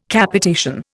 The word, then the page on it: Capitation: Indian